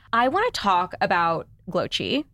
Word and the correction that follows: Glowcy: Is that how you say it? – Glowcy. – Glowcy. – Yep. – Oh, close. So